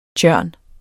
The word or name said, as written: Crataegus